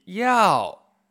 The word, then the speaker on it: 要: 想 y yao y-A-O. Yao. Cuarto tono. Hacia abajo, ah, como un tilde español ah, en la segunda sílaba.